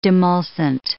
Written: demulcent

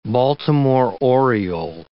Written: Baltimore oriole

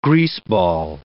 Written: greaseball

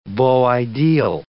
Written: beau ideal

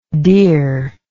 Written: dearness

